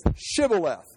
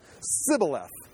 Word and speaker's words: Shibboleth: The password is Shibboleth. Yeah. Sibboleth. Oh, sorry. And they killed him, because he could not pronounce the SH sound.